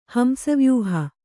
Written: ಹಂಸವ್ಯೂಹ

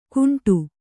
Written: ಕುಂಟು

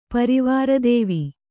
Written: ಪರಿವಾರದೇವಿ

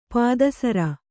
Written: ಪೀತಸಾರ

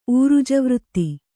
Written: ಊರುಜವೃತ್ತಿ

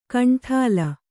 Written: ಕಾಂಡಲು

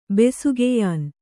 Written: ಬೆಸುಗೆಯಾನ್

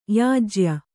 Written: ಯಾಜ್ಯ

